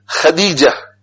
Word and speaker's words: Khadija: Khadija, not Khadija. Khadija, Dal. And every Muslim is able to pronounce Dal. Non-Muslims will find it difficult to pronounce Dal because there is only D. There is no D. But we learn the Quran, Alif, Ba, Ta, Sa, Jim, Ha, Ha, Dal. It's very easy for us to pronounce Dal, Khadija.